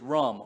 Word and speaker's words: rum: Funnily, the Hebrew word for haughty is rum, R-U-M. It's pronounced R-O-O-M from what I gather.